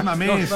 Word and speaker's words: Mamés: O el otro es No Mames. – Mamés. – Un acento. Pronuncia, sí, correcto.